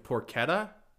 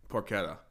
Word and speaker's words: porchetta: Are you thinking of porchetta? – Yep. – That's where you roll the pork. – But that's porchetta, I thought. – Porchetta. – It's pronounced porchetta? – I believe so.